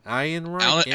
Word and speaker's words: Ehrenreich: There's all this controversy and turmoil about the film, about the directors, about the script, about the actor, Alden Ehrenreich. – Is that how you say his name? – Alden. – I've just been saying like Fourth Reich all along. – It's Alden something. Ehrenreich.